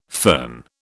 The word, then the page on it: fern: British English